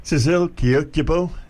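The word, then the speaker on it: Sissel Kyrkjebø: Cecil Kiokjebo, Norwegian pronunciation. I've got it pretty close, I think. I've been practicing it.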